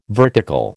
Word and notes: vertical: American English

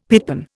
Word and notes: pitpan: Indian

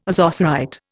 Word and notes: ozocerite: Indian

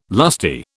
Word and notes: lusty: British English

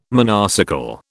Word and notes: monocycle: British English

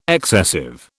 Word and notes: excessive: British English

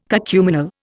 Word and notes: cacuminal: Indian

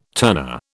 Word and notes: turner: British English

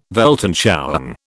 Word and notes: Weltanschauung: British English

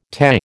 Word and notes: Te: American English

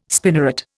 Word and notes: spinneret: Indian

